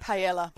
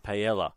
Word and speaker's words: paella: Paella. – Paella.